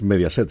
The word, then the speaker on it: Mediaset: Jorge, vamos con Mediaset. Sí, sí, lo he dicho bien, no lo he dicho mal. No, no, no, es Mediaset.